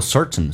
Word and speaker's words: certain: 被强调的黑色字体主重元音要放大，比如说 certain, certain, darkness, darkness, r, r, 都要放大一点，来体验一下节奏感。 Certain darkness, T 跟 N 在美式发音连在一起的时候， T 可以轻轻的，气流形成阻塞，似听到没有听到，变成 certain。